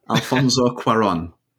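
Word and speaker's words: Alfonso Cuarón: Alfonso Cuaron. – That's correct.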